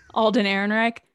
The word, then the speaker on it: Alden Ehrenreich: Alden Ehrenreich.